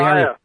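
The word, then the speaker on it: Taia: Is it TIA Global? TIA Global? Welcome back, Jeffrey. – TIA. – TIA. TIA Global, Inc.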